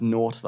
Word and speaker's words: Gnort: It's pronounced Gnort, though, apparently. – Is it? Yeah, the G's.